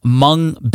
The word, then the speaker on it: Hmong: Let's get into today's featured content, which is an interview with the founders of Hmong Baby. That's with a silent H in the beginning. That's H-M-O-N-G, Baby,